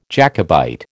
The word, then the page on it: Jacobite: American English